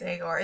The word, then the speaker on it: Agor: I think it's Agor. It's spelled like Igor, except with an A instead of an I at the beginning.